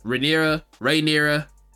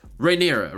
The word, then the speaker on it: Rhaenyra: Everybody says Rhaenyra, just Rhaenyra, right? He found some other way to say her name, and I was like, "Ayo, dog, nah, man, like let's."